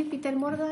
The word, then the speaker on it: Peter Morgan: Hemos visto 10 capítulos, que son los de la primera temporada, y el creador es Peter Morgan, ¿se dice así Peter Morgan? – Peter Morgan, no tiene mucha dificultad este bueno, este nombre.